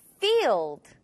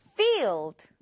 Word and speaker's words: field: 가끔씩 F 발음을 너무 신경 쓰다가 F가 P 소리가 날 때가 가끔씩 있어요. 잘 신경 쓰고 F, F, field. 그리고 마지막에 D는 드, field. 이렇게 하지 않고 field 하고 날려주세요. One more time.